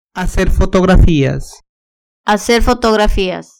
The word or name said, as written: hacer fotografias